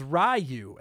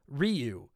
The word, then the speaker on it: Ryu: I always pronounced Ryu from Street Fighter as Ryu and not the correct Ryu.